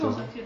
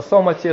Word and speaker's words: самадхи: Самадхи, да? Нет, самадхи на санскрите звучит, а это самати, это уже несколько такие тибетские формы произношения этого слова.